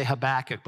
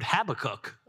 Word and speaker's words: Habakkuk: I always say Habakkuk, but I've heard Habakkuk. I'd never heard that before.